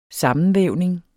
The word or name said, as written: sammenvævning